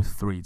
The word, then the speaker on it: three: 其实除了母语的英文之外，在其他的地方有很多的人，他们也没有办法很清楚的发出 three 这个字，尤其是非常大的这个印度的英文的使用者，他们常常会把它念成 tree tree tree tree tree。他们的那个 th 也是不容易发出来的声音。